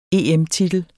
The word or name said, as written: EM-titel